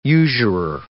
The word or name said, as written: usurer